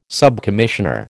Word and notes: subcommissioner: American English

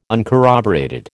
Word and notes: uncorroborated: American English

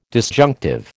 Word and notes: disjunctive: American English

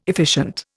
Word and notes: efficient: Indian

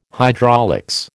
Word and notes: hydraulics: American English

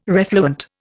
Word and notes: refluent: Indian

